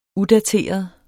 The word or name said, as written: udateret